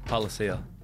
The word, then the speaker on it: Palasia: Palasia.